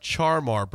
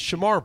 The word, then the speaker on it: Shamar: It's not Charmar Brown. Shamar Brown is simpler. He called him. It's Shamar Brown.